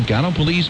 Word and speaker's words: Gallipolis: but I can tell you that I learned early on how to pronounce the U.S. Naval Academy city of Annapolis. And if you know how Gallipolis is spelled, you'll see where my confusion came in.